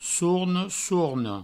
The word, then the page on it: sorne, sorna: sourne; sourno